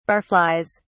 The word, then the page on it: barflies: British English